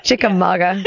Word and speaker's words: Chickamauga: in Chickamauga, Georgia. Did I say that right? – Chickamauga. – Yeah. Chickamauga. Chickamauga. – Yeah. Yeah, it's a Cherokee word.